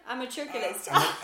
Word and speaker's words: amatorculist: June McBride divorced her husband because he was a matriculist. I keep forgetting how to say it. What did I say? – Amatorculist. – Amatorculist.